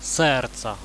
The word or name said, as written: serca